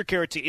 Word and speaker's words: Caratini: Is that how it's pronounced? I know he's a former Cub. – I believe Caratini is right. – Yes, that's correct. – There you go. Victor Caratini.